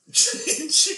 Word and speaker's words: Chicopee: Chicopee.